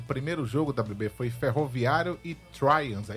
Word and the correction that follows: Tramways: é isso que pronuncia? – Tramways.